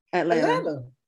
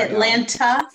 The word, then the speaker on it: Atlanta: And maybe it's Atlanta. You get us corrected. How do you say it? – Atlanta. – I was saying it with the hard T at the end. I'm sorry. – Atlanta.